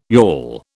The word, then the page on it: yawl: British English